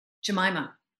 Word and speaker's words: Jemima: Is it Jemima or Jemima? – Jemima.